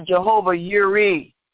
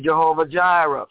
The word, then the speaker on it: Jehovah Yireh: I know some of you all want to know that we know that we know he's our provider, our Jehovah Yireh, as it's correctly pronounced in Hebrew. We often say Jehovah Jireh.